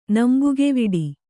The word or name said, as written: ನಂಬುಗೆವಿಡಿ